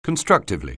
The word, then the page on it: constructively: anglais